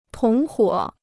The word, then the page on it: 同伙: tóng huǒ